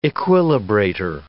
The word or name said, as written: equilibrator